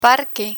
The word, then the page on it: parque: Spanish